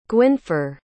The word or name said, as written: gwynfor